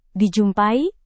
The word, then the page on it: dijumpai: Indonesian